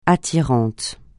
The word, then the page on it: attirant: francais